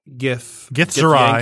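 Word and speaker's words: Githzerai: We have the Gith Zerai. – Yankee and Gith Zerai. – Yep. – Yes. If I'm saying it right. You are correct.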